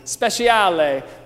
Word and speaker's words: Speciale: So our life philosophy today, it comes from Sarah Beth Special, or as she told me the other day, it's actually pronounced, if you're Italian, speciale, which I really love. And so I didn't know, she has an E on the end of her name, and so I wasn't exactly sure.